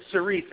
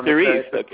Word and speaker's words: Cerise: Not quite. It's Cerise, actually. – Cerise,